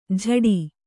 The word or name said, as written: ಝಡಿ